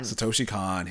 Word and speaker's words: Satoshi Kon: Satoshi Kon. – Satoshi Kon.